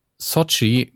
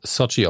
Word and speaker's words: Xochitl: Äh, äh, Sochi Gomez. Ich glaube, es wird Sochi ausgesprochen. Schreibt sich X-O-C-H-I-T-L. Ich glaube, es ist Sochi. Ähm, oder Sochi. Auf jeden Fall die junge Frau Gomez war zur Zeit der Dreharbeiten 15 und die spielt richtig, richtig stark.